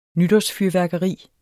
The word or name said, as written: nytårsfyrværkeri